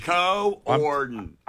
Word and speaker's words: coordinated: There's an extra syllable in there. I'm just saying. It's Co-Orden.